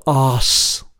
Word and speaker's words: ASS: We heard a band called Oss. Oss, Uh, spelt A S S, but pronounced Os, featuring, amongst others, Brian Turner from WFMU.